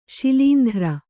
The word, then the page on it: ಶೈಲೇಂದ್ರ: Kannada